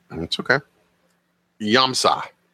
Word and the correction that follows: Yamasa: It's Yamasa. And I put on there it's the most mispronounced cigar in the industry because I listen to podcasts all the time. I listen to, you know, quote unquote our competitors' podcasts, and everybody pronounces it wrong.